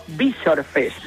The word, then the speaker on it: Visor Fest: No, Yabrés me dijo Visor Fest, no Visor. – Vale, Visor Fest, o sea, con la con el acento en la I.